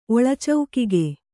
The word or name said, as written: ಒಳಚೌಕಿಗೆ